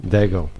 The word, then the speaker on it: Daigle: Beautiful, gorgeous song by Lauren Daigle. Daigle? Daigle? I don't know if I say it right. – Daigle. – Oh, thanks. Thanks.